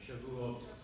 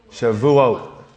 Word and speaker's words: Shavuot: Last night began the feast of Shavuot, if I say that right. Is that correct? Say it one more time. Shavuot. Shavuot. Close enough.